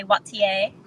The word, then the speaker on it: Waiter: I said it like a French accent, like Wattier. – You know, my husband is French Canadian, and so I like to say Wattier.